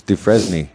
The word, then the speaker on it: Dufresne: It's Dufresne.